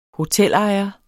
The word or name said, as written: hotelejer